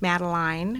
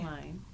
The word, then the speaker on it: Madeline: And her name's Madeline mm-hmm. with a long I. – Madeline, yes.